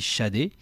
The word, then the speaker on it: Sade: Ça s'écrit Sad, mais ça se dit Sade.